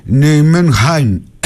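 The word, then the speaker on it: Nijmegen: Η Ολλανδική όμως, λέει, προφορά είναι Νιουμενχάιν, είναι κάπως βαθύ το χ, δεν μπορώ να το πετύχω.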